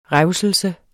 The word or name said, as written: revselse